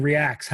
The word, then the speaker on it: ReAX: And you're saying Reacts. How do you spell that? R-E-A? – Capital R, lowercase e, capital A, capital X.